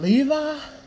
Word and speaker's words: Levi: And he was like, "Oh, you mean Levi?" I was like, "Oh shit, that's his name pronounced." I couldn't, I couldn't get it down, and then I couldn't. Uh, it took a minute for me to be able to say it because of the Levi jeans.